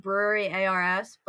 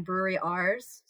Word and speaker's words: Brewery Ars: So they had what I've always called Brewery ARS, but the announcer at the starting line kept saying Brewery Rs. So I guess that's how they say it, but it's literally like capital ARS.